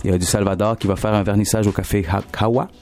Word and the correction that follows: Kawa: Comment on prononce ça? – Kawa Café. – Kawa, ouais. – C'est Kawa Café.